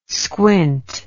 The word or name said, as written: squint